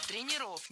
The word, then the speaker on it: тренеров: Тренеров, неверно. Перед нами, опять же, слово с неподвижным ударением. И двигать это ударение мы не должны. Всегда ударяем «е» в первом слоге. Тренеры, тренерам, тренеров. Похожая ситуация и у других специалистов.